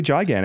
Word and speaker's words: Gigan: Yeah, it's officially Gigan, actually, if we're going from the novel.